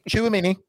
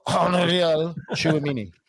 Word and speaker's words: Tchouaméni: Tchouaméni. – It's great. Honestly, you improve every time. – Well done. – Unreal. Tchouaméni.